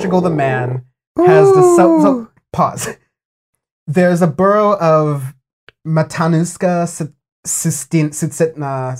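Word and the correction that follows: Matanuska-Susitna: Matanuska Susitna.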